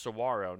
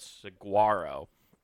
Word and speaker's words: saguaro: But that's what it's supposed to, but it's pronounced saguaro, not saguaro.